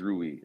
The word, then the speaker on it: Rui: So as we're currently constructed, you know, and obviously with the do we pronounce his name Rui? – It is Rui.